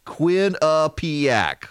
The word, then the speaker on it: Quinnipiac: K-W-I-N, Quinn, U-H, P-E-A-P. A-C-K, ACK, Quinnipiac, the Quinnipiac University National Poll.